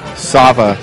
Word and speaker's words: Sava: M-I-D-O-R-I. – And your name, sir? – Sava, S-A-V-A.